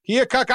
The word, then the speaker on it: Keokuk: Water came from Keokuk. Is that how you say it? Keokuk, Iowa? – Yep.